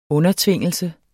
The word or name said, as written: undertvingelse